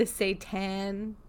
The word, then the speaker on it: seitan: And that brings us to the seitan chicken.